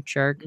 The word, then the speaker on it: Chark: Chark.